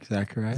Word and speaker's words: Zechariah: I said Zech. – Zach. Zechariah. – Zechariah. I don't know where I went. – I don't know. – Priest. – Okay. Zechariah.